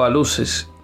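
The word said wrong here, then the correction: aluxes: bueno, aluches, Pasa que es con X. Pero no, la, ustedes saben que la X se pronuncia como CH, aluches.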